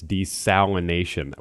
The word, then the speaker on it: desalination: I have to correct you because you made the same mistake that I made in saying desalinization. It's desalination. We keep throwing, it's a hard word. I keep throwing that extra syllable or two in there, desalinization, but it's not.